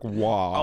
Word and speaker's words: Roy: Patrick Roy? What? – Patrick Waugh. – Oh, I'm sorry. – Yeah. But it's spelled Roy.